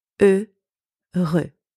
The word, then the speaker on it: heureux: And finally, we have the letter X, which is silent in that word. So if we put everything together, that gives us the word heureux.